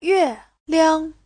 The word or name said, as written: yue4liang5